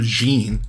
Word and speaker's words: Jeanne: His mother, Jean, I think that's how you say it, J-E-A-N-N-E. Yeah, I think it's Jean.